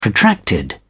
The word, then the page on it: protracted: British English